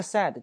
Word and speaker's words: said: 注意这个 "said" 这个词的发音，它是一个单元音 "a"，对吧？"said" 我上堂课说过，对吧？"I said" 我说啊，说 "The play may begin at any moment"，这个细语啊，很快就会开始了，任何时候都可能开始。